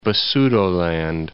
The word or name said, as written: Basutoland